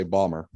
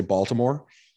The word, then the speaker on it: Baltimore: People from there say Balmer, but. The rest of us humans in the United States usually say Baltimore, but I really love calling it Baltimore.